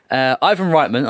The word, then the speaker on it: Ivan Reitman: Ivan Reitman, I hope I pronounced that correctly.